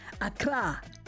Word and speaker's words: Accra: Lots of love to Ebenezer, my brother Ebenezer, listening in Accra. Did I say that right? Accra. Yes.